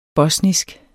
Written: BOSNISKE

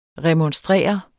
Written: REMONSTRER

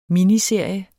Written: miniserie

